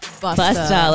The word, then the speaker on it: Busta: Hey everyone, I am Matt Wilhelmy, and today we have a wonderful guest, Melissa Busta. Did I say it right? – Busta, like Busta Rhyme. – Melissa Busta.